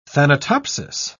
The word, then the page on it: thanatopsis: British English